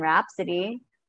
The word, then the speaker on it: Rhapsody: Uh, can you repeat that for me to try? – Bohemian Rhapsody. – Bo- Bohemian Rap. Rap oh my gosh. Raps- Rhapsody. – Rhapsody. – Yeah, Rhapsody. Rhapsody. Oh my gosh.